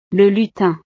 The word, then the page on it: le lutin: frances